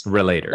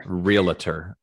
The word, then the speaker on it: Realtor: And I can pronounce it wrong sometimes. – Relator. – Like relator. – Realtor. – Yes.